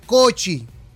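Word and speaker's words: kocsi: Y los, húngaros llamaban a ese tipo de carroza tirada por caballo, la llamaban Sekel, pero la palabra coxi se pronunciaba cochi, como ah.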